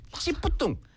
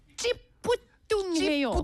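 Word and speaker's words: 찌뿌둥: 이거 오늘 배웠습니다. 네. 찌뿌뚱. 찌뿌뚱. – 찌뿌뚱해요. – 찌뿌뚱해. – 찌뿌뚱해. – 네. 찌뿌뚱. 이게 이렇게 쌍이 뭐라지? 쌍지지 and 어. 쌍이 비읍부.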